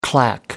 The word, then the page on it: clack: American English